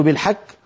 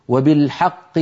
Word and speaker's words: الحق: هل أنزل القرآن بالحك ولا بالحق؟ وبالحق أنزلناه وبالحق نزل وبالحق، ولا نقول بالحك.